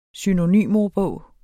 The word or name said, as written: synonymordbog